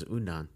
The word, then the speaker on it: udon: Except in ramen form versus udon. – Yeah. – Did I say that right? – Udon. – Yeah. Udon.